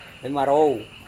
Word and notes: vemarou: Serewen

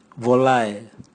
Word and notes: volae: Raga

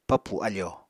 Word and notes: papù afò: Apatani

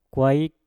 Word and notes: kwaik: Mpur

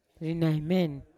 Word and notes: rinaimen: Yawa

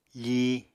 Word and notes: fíi: apatani

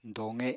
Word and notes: doqe: apatani

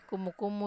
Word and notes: kumu-kumu: Wamesa

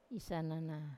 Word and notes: isanana: Wamesa

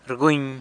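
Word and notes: rguny: San Lucas Quiaviní Zapotec

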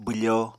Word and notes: bwfo: apatani

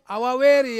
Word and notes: awaweri: Ansus

